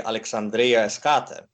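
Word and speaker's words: Alexandria Eschate: I can't pronounce this Greek name, so I need your help here. – Yeah, it's Alexandria the furthest, which would be Alexandria Eskate.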